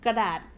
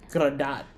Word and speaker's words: Kradas: So, Kradas? Kradat. Mm-hmm. Okay. I thought it was like, yeah, look at the Kradas Cafe. Kradat. – Yeah, Kradas. It is spelled like Kradas, but it's Kradat.